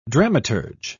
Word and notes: dramaturge: British English

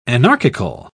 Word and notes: anarchical: British English

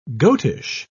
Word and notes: goatish: British English

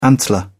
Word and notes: antler: British English

